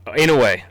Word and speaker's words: Inoue: I got the monster Inoue at 4. I'm butchering his name. – Oh, Inoue.